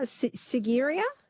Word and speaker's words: Sigiriya: Sigiriya? – Yes.